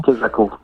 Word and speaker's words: Kesako: Ce nouvel album s'appelle Kesako, j'espère que je le prononce correctement. – Kesako, oui, c'est ça.